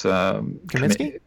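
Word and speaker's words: Kaminski: Kaminsky.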